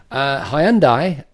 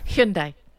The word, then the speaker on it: Hyundai: Hyundai, I'm not saying that right, am I? – Hyundai. – Hyundai.